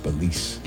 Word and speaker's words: Belize: Belize. Could you spell that? – B-E-L-I-Z-E.